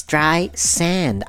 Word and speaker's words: Streisand: It's Streisand. I don't understand what's so complicated. It's Streisand. Sand. It's in the word.